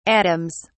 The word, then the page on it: atoms: British English